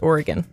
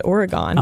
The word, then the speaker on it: Oregon: Well, as someone hailing from the Pacific Northwest, I want to tell you that it's pronounced Oregon. – What did I say? – You said Oregon.